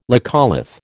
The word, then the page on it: laccolith: American English